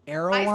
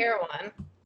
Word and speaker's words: Erewhon: Erewhon. – I say Erewhon.